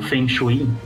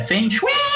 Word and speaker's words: Feng Shui: Não, eu estava lembrando, tem um RPG chamado Feng Shui. Não, mentira, a pronúncia é Feng Shui! Não, eu não tô inventando, realmente essa é a pronúncia do jogo.